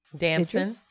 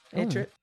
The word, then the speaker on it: Damson Idris: Damson. – Idris.